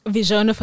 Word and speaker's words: Visiona: And then you mentioned kind of in answer to my last question that you do have a photography business, which is Visiona Photography. Am I right? – It's Visiona Photography. – Visiona Photography. I was like, am I pronouncing this correctly?